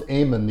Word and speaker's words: Eamon: Yeah, well, actually it's pronounced Eamon. The Eamon. E is silent.